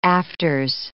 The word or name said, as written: afters